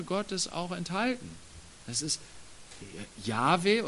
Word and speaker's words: Yahweh: Manche sagen, dass dieser Name Yahweh ausgesprochen wird.